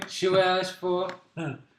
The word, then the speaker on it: Chiwetel Ejiofor: You have Bruce Dern, Chiwetel Ejiofor. – Wait, how do you say that again? – Chiwetel Ejiofor.